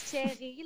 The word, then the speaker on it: Thierry: It's Thierry, like the French, but the Dutch say his name is Thierry. – Like the fruit.